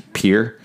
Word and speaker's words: Pierre: It's like people from South Dakota, the capital's Pierre. – Oh, Pierre, not Pierre.